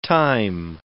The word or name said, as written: thyme